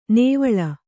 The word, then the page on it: ನೇವಳ: nēvaḷa